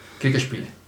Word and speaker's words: Kriegsspiel: ¿Y cómo se diría en alemán? – Kriegsspiel. – Es que aquí el chaval pronuncia muy bien.